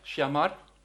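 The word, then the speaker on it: shamar (שמר): Shamar. En het werkwoord shamar, dat spreek je hetzelfde uit, dat betekent zoveel als in de gaten houden.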